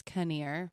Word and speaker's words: Kneer: At Kara Kinnear on Twitter. My last name is K-N as in Nancy, E-E-R. – So K-N-E-E-R. – People always pronounce it near, but it's Kinnear. – And it's Kara, C-A-R-A.